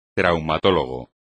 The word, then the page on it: traumatólogo: espagnol